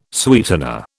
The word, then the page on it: sweetener: British English